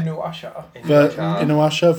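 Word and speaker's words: Inuyasha: So this is Inuasha, am I saying it right? – Inuasha versus a Naruto character.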